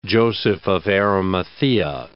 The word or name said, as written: Joseph of Arimathea